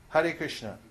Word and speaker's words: Hare Krishna: Hare Krishna.